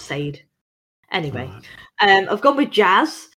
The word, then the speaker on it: Sade: Very weird because it's spelled S-A-D-E. I just don't understand how they've decided that that's how it's going to be pronounced. – Well, that's how I pronounce it. I'll be wrong all these years. – But where are you getting the sh from? – I'm sure that's how it's pronounced.